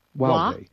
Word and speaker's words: Huawei: So, Huawei, did I say that correctly? – Huawei. – I cannot get that in my head.